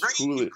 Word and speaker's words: Julius: I thought it was Julius. Great name, by the way. – Yeah, great name. I thought it was it's like a soft J, not like a hard J.